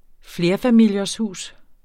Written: FLERFAMILIESHUS

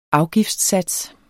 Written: afgiftssats

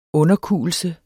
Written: underkuelse

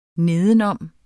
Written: nedenom

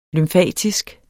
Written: lymfatisk

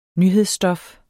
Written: nyhedsstof

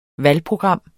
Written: valgprogram

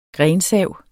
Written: grensav

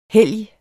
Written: helgen

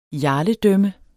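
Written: jarledømme

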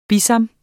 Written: BISAMPELS

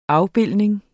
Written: afbildning

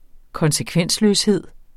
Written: konsekvensløshed

